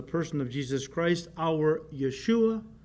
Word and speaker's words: Yeshua: In fact, the Greek name for Jesus comes from Jehovah Shua, or as the Jews pronounced it, Yehoshua, or they would shorten it to Yeshua.